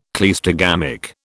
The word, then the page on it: cleistogamic: British English